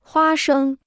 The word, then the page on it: 花生: huā shēng